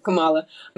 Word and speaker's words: Kamala: And it's funny because I always have to remember how to pronounce her name correctly because somebody told me it's like comma and then love. Kamala.